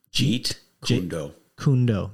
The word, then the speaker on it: Jeet Kune Do: Well, you got your Keet Jundo. You've got am I saying it right? – Jeet Kune Do.